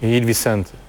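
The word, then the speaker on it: Gil Vicente: G ile başlıyor ama Hil. – Hil Vicente.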